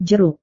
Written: jeruk

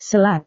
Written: selat

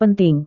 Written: penting

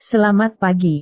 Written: selamat pagi

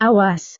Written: awas

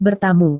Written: bertamu

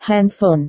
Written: handphone

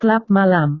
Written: klub malam

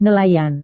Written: nelayan

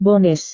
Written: bonus

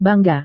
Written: bangga

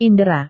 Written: indera

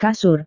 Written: kasur

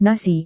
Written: nasi

